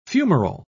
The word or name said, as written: fumarole